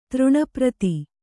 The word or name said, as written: ತೃಣಪ್ರತಿ